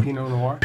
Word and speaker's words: Pinot Noir: Ammunition, what they do, so it's in a, like this one is in a pinoir. Is that how you say it? – The Pinot Noir.